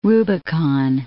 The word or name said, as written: Rubicon